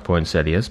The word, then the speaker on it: poinsettias: It is pronounced poinsettias, by the way.